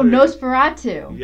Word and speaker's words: Nosferatu: Oh, Nosferatu.